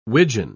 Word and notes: wigeon: British English

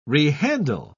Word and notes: rehandle: British English

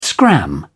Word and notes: scram: British English

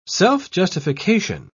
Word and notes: self-justification: British English

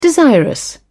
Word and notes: desirous: British English

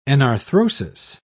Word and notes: enarthrosis: British English

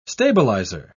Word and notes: stabiliser: British English

